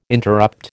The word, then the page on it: interrupt: American English